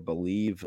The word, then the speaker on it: Bleav: I want to talk quickly about our new partner here, Believe. Again, it's spelled BLEAV, and we're excited to be working with the company there.